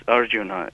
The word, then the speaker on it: Arjun: three different pronunciations of the central character of Bhagavad Gita, which is Arjun. And I heard Professor Narayan call it Arjuna, but I would like to let her know that there is no such word in Sanskrit language as Arjuna.